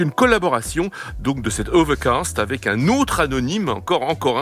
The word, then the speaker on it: OVRKST: Voici maintenant la voix d'un jeune rappeur de Oakland, c'est une ville qui était tout près de San Francisco, qui signe Overcast, ça s'écrit O-V-R-K-S-T.